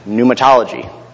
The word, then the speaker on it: pneumatology: And you guys know that Michael has shared with you this morning, we will be looking at the Holy Spirit, which is pneumatology. You can see there it's P-N-E-U. The P is silent, like the silent P in swimming.